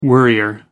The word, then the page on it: worrier: American English